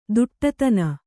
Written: ತುಡುತಿನ್

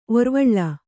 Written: ಒರ್ವುಳಿ

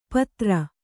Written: ಪತ್ರ

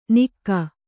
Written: ನಿಕ್ಕ

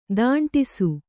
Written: ದಾಂಟಿಸು

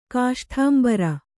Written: ಕಾಷ್ಠಾಂಬರ

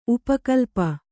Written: ಉಪಕಲ್ಪ

